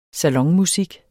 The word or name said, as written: salonmusik